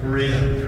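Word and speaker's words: Fariña: Farina. – Farina, yeah.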